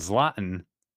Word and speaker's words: Zlatan: Z-L-A-T-A-N? – Yeah.